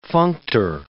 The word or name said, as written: functor